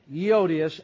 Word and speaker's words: Euodias: Euodias and Syntyche, that they be of the same mind in the Lord.